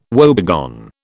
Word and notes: woebegone: British English

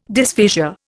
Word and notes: dysphasia: Indian

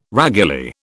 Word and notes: raguly: British English

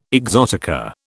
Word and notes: exotica: British English

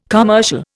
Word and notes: commercial: Indian

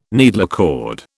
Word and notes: needlecord: British English

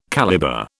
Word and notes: calibre: British English

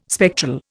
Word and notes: spectral: Indian

